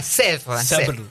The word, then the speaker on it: Sèvres: El acentito, le erraste con el acento. Sèvres. Sèvres. – Sí. ¿Qué es Sèvres? Hay un centro internacional de estudios pedagógicos en la localidad de Sèvres, que es en el conurbano de París, y la beca mía era para un ciclo lectivo, o sea, 9 meses.